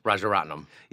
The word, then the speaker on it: Rajaratnam: Rajaratnam.